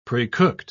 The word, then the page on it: precooked: British English